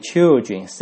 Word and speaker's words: children: 还有呢就是小孩了， child，然后变成复数呢就是 children，你看那个发音有改变哦，那个 I 字母的发音变成 children， children， children，好吧，小孩。